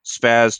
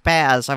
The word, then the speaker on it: SPAS: That's the first time I've ever heard it pronounced spaz. I've always heard it spas. – Yeah. That's because that's the right way to say it.